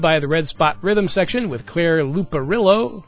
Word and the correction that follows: Liparulo: Let me see if I can get that name right. Liparulo. I mispronounced it, and I apologize.